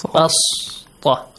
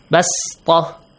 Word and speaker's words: بسطة: بسطة, right? – Yes. – Listen, how do I read it? بسطة. – Yes. – Pronounce it like a sin.